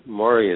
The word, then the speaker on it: Maurie: Mauri is spelled M-A-U-R-I-E. Pressman is P-R-E-S-S-M-A-N, one word.